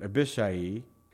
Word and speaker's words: Abishai: And there were three sons of Zeruiah there, Joab and Abishai, I think is really how you say it, Abishai, and Asael. These are the Hebrew pronunciations.